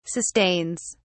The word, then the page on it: sustains: British English